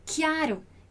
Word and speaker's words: chiaro: Então, a gente tem aqui o CH do chiaro, que tem esse nosso som do QU, né? Ou o nosso som do K, seria o som como da letra K. Então, chiaro, essa é a pronúncia correta.